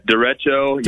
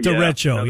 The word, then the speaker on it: Derecho: Derecho. – Derecho, yeah.